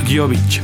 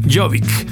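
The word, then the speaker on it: Gjøvik: pero se llama Gjovik. Gjovik. Espero esté bien pronunciado. se escribe G-J-O. Es la O esta con la. con el slash atravesado. V-I-K. Jovic.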